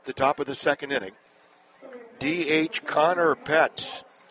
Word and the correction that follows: Ploetz: or I should say Pletz, a freshman steps in, spelled P-L-O-E-T-Z. You don't pronounce the O.